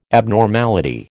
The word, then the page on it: abnormality: American English